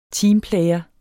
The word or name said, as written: TEAMPLAYER